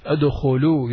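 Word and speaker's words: ادخلوا: It's silent alif. It's a silent alif. أدخلوا, you don't say أدخلوا وا. No, أدخلوا only. And that alif at the end is just a silent alif. It's not pronounced.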